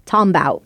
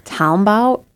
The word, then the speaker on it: talmbout: It's a phonetic spelling representing an African-American vernacular English pronunciation of talking about, tombout, used especially online. – Tombout? – Tombout.